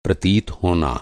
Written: प्रतीत

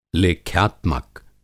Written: लेख्यात्मक

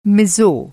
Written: मिज़ो

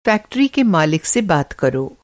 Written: मालिक

